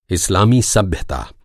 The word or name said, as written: इस